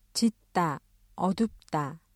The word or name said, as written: 짙다, 어둡다